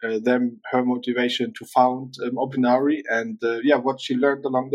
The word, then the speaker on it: Opinari: Gretchen is a founder and the CEO of Opinari.